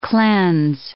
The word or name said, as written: cleanse